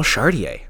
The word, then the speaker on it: Chartier: We have Paul Chartier on the program tonight. – Hey, you fucking nailed the pronunciation.